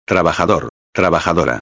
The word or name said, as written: trabajador-a